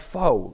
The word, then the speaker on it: pho: And I'm going to pronounce it the English way, because if I pronounce it the natural way, I sound like a git. Pho. – Oof, pho. – Or as they say locally, pho.